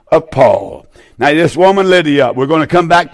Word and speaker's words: Lydia: And a certain woman named Lydda. I'm not saying that right. Lydia. I got it now.